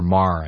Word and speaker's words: Maher: And or Maher, I guess it's pronounced.